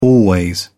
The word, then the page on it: always: British English